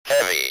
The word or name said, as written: heavy